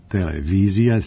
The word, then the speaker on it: televizija: Ja privatno kažem televizija, Jugoslavija, međutim po normama našeg jezika i kad sam pred mikrofonom moram da kažem televizija, Jugoslavija i postoje pravila jezička kod tih složenica, kad je silazni akcenat na kraju, televizija, silazni, onda se akcenat prebacuje napred, televizija ili Jugoslavija.